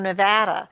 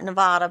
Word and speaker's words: Nevada: I remember once I was doing a workshop in Reno, Nevada,